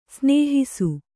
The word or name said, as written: ಸ್ನೇಹಿಸು